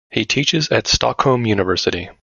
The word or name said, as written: teaches